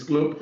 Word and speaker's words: Klopp: It's Klopp.